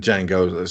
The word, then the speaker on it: Django: He was the guy who turns up in the bar after the horrible wrestling match in Django Unchained, and Django say like the D is silent, and that's Franco Nero.